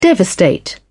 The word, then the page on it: devastate: British English